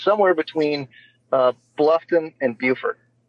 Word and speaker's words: Beaufort: It's Beaufort in South Carolina. – Yeah. – In North Carolina, it's spelled the same and they call it Beaufort.